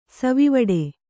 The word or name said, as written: ಸವಿವಡೆ